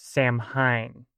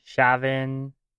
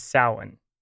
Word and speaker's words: Samhain: And I know what you might be thinking because, at least phonetically in English, Samhain looks like it should be pronounced Samhain. And, yeah, a lot of people do make this mistake. But there's actually more than a couple different ways to pronounce it. Like Samhain, Shavin, Samhain, Samhain, among others. But the standard Irish pronunciation is Samhain.